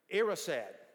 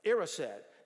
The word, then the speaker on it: Erised: and the mirror is The mirror is pronounced Erised. I think that's right. Erised. Now, this is a children's movie, you understand? And so all that is desire backwards, right? That's just spelled backwards.